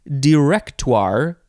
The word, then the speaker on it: directoire: It is directoire, or directoire. You can emphasize the second syllable or the third syllable. I feel like emphasizing the third syllable is the best way. Directoire. Capital D-I-R-E-C. T-O-I-R-E.